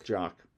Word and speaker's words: Jock: So him and do they pronounce it Jacques or Jock? – I think it's Jock. – Jock, who also worked on Black Mirror.